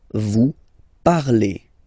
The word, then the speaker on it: parlez: Remember, a Z, when you combine them together, you get the sound E, vous parlez, all right? And the last one, so even if you've got this E-N-T, don't hate me, but you won't pronounce these letters, okay?